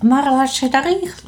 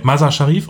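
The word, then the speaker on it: Mazar-e Sharif: Ich glaube, das hast du falsch ausgesprochen. – Danke, dass du mich korrigierst. – Kein Problem. – Wie heißt es richtig? – Mazar Sharif. – Mit 3F. Nein, Mazar Sharif.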